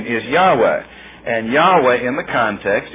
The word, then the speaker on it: Yahweh: And let's say if we can make a shift over, we say Yahweh rather than Jehovah. Jehovah is, in all likelihood, not the correct pronunciation for God's name.